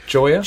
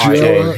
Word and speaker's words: Gioia: I'm not sure of his name. – Gioia. – It's pronounced Gioia.